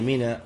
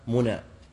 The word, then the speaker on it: منى: طيب، إذا وصل منا، ومنا مشعر من المشاعر، وسميت منا لكثرة ما يمنى فيها من الدماء، يمنى يعني يراق، وهي منا وليست كما ينطق بعض العامة منا.